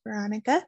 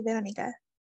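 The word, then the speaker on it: Verónica: I introduce myself as Veronica, but it is actually Veronica. And a lot of people that speak Spanish that call me Veronica, I'm like, oh, finally, somebody says my name correctly.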